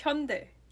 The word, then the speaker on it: Hyundai: Like, it's Hyundai. – Hyundai? Right? Yeah, I was pronouncing it right all along. – You maybe made it more of a die, which most people do.